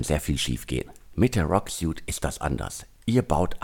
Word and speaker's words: Roq: Die heutige Ausgabe wird präsentiert von Rock Technology. Rock schreibt man R-O-Q.